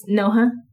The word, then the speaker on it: Noha: Why did you say it like that? – Noha. I mean, that's not really how you say my name, but I just try to say it like... – Well, how do you really say your name? – It's Noha. – No, but like when I say like that, people just say call me Noah all the time.